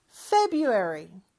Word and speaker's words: February: February라고 하는 경우는 거의 없어요. 그 R은 그냥 묵음 처리해 주시면 돼요. February.